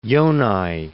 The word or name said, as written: Yonai